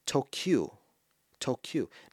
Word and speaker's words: Tokyu: If you put it together, it's to-kyu. Now, what does that sound like? Tokyu. Doesn't it sound like Tokyo? Only the final syllable is different, right? Tokyo, Tokyu.